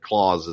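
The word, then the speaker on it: Clase: But I am fairly confident that Clause is how you say that one.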